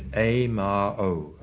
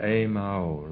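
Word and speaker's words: A-ma-o (ཨེ་མ་ཧོ): And the mantra-like exclamation is A-ma-o, which is sometimes pronounced quickly as a single word, A-ma-o, like that.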